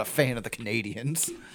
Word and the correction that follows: Canadiens: I guess it's pronounced the Canadiens.